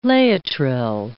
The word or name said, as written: Laetrile